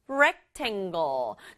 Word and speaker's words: rectangle: rectangle. 조금 어렵죠? 단어가 기니까 rect 부분에 강조해서 rectangle.